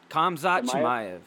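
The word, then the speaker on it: Khamzat Chimaev: How do you say his name? Chimaev. – Kamzat Chimaev, yeah.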